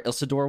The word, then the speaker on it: Isildur: Is it going to be the age where Isildur was alive? – And Isildur. – Yeah, so that's Isildur. Isildur. – Isildur. – Isildur. – Say it like Spanish.